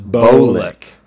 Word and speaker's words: Bolick: Bolick. – Yes. And fun fact, it's a long O, Justice Bolick.